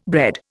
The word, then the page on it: bred: Indian